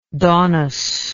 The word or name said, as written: daunus